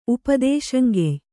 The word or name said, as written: ಉಪದೇಶಂಗೆಯ್